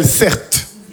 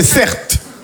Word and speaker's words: Certe: Je m'appelle Certe. Mon prénom, c'est Certe. Ça s'écrit C-E-R-T-E.